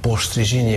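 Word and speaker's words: Postřižiny: Rövidíteni kell, mint a Rábál Sörgyári kapricsóban, aminek azt hiszem, hogy az eredeti cseh címe az az, hogy Postri Zsinyi, azt hiszem így kell kiejteni, hogy rövidíteni, vagy rövidítés.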